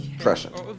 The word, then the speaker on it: prescient: Prescient should be pronounced prescient. – prescient. – It's an SH sound.